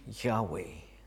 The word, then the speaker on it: Yahweh: In Hebrew, the name is four letters, our equivalent of YHWH, and it was lightly pronounced Yahweh.